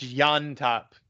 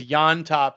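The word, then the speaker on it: Llontop: I believe it's Yontop because double L's is like a Y. – Hopefully you're not still talking because you cut out and I didn't hear anything you said there. Did I say his name right? James Lontop. – No, I was just correcting you to tell you it's Yontop because double L's are like right. A Y in Spanish.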